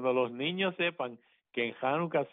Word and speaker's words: Hanukkah: Es más, en realidad, la forma actual de pronunciar es raspando tu garganta y es Hanukkah. – Sí. Okay. Y entonces, como el campestre cuando dice cajo.